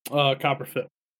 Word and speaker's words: Copper Fit: Also has had this special Cooper Fit knee brace on all day that's infused with methanol. – Is that Cooper Fit or Copper Fit? – Copper Fit. Sorry about that. Mispronounced the word there.